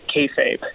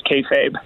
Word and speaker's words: kayfabe: It's, so I I should let's start by pronouncing it kayfabe. It's okay. a it's a funny word uh, I guess we'll probably get into what it all means in a moment, but it is kayfabe.